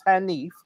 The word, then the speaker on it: Henif: It's spelled H-E-N-I-F, pronounced Hanif.